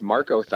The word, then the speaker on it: Marcothon: Now, am I saying this right? Markothon? – Yeah, that's correct. Markothon.